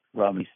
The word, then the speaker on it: Romani: Romani studies courses. – Something you point out early in the book is the proper pronunciation of Romani, similar to hominy in English.